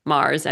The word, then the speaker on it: Mars: It's Rebecca with two K's, R-E-B-E-K-K-A, Mars, M-A-R-S, all one word, .com.